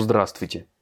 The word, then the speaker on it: здравствуйте: Pareil pour здравствуйте. C'est pas sur le Y à la fin, c'est sur le A au début.